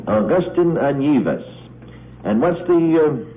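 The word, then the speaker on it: Anievas: His name is Augustin Anyevus.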